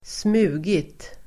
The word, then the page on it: smugit: [²sm'u:git]